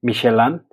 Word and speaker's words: Michelin: Como bien dices, eh, esta guía y esta empresa es, es, francesa, entonces pues se puede decir Michelin, si es que lo quieres pronunciar en francés.